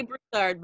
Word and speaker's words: Broussard: Say Broussard, boo. – Broussard. – You used to say it wrong. Very Yankee-ish. – I said it right that time. – Broussard. Like, nope, it's Broussard.